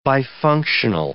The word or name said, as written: bifunctional